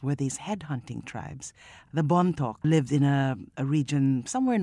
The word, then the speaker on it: Bontoc: Well, Bone Talk is set in a place called Bontoc. In fact, the title is how Americans mispronounced Bontoc when they came and invaded the Philippines in 1898.